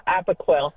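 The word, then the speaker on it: Apoquel: Amazon, and it is actually Apoquil. – Yeah, Apoquil.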